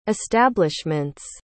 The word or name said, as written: establishments